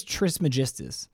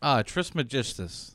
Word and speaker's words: Trismegistus: It took me a few weeks to learn how to say this properly, but his name is Hermes Trismegistus. – Trismegistus.